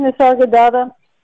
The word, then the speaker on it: Nisargadatta: Nisargadatta? – Yeah.